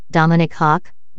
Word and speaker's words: Dominik Hašek: Dominik Hašek, Czech pronunciation, Dominik Hák,